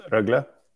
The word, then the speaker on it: Rögle: Actually, I think, you know, first and foremost, it's pronounced Rögle. You said Leksand. Great. You didn't say IF. So don't pronounce the BK as well.